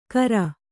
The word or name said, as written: ಕರ